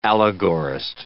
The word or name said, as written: allegorist